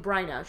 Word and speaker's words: Bryna: Is a white woman, Miss Taylor, very, very sweet, sweet woman, looked at me and she's like, okay, how does your parents say your name? I'm like, oh, they say my name Bryna.